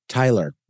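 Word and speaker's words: Tytler: But no, the second edition is when the editor, James Tyler, but with 2 T's for no good reason. No good reason there. Let's just throw a second silent T in there just to zhuzh it up a little bit.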